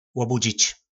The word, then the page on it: lobudzic: po nasimu